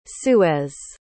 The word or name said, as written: suez